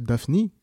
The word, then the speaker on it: Daphne: D'ailleurs, la réelle prononciation de son prénom c'est Daphne, mais en tout cas, cela en dit long sur son impact dans les charts et sur son influence.